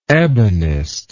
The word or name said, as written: ebonist